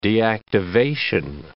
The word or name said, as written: deactivation